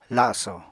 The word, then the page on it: laso: apatani